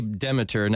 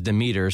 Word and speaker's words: Demeter: Yes, we do say Demeter. Now, we know the Greek pronunciation is Demeter, so we're not offended if you want to say Demeter.